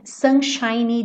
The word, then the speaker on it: sunshiny: Não esquece desse Y, tá? Sunshiny. Porque ele tá fazendo o adjetivo, lembra que eu falei pra você? Sunshine, termina com E e o E é mudo, seria o brilho do sol, tá? E sunshiny tá fazendo o adjetivo. Então, esse Y tem que aparecer. Sunshiny day, sunshiny day. Não é a sílaba tônica, mas tem que aparecer.